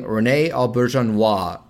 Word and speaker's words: René Auberjonois: René Auberjonois.